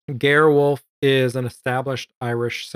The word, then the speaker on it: Ger: Unless I'm pronouncing his first name wrong, which might be Ger. It's G-E-R. Maybe okay. It's Ger. But his last name is Wolf.